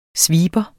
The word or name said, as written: sweeper